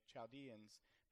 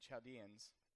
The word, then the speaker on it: Chaldeans: The king answered and said to the Chaldeans, am I saying that right, Chaldeans, or is it Chaldeans? Chaldeans, all right.